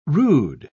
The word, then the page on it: rood: British English